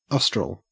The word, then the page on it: austral: Australian English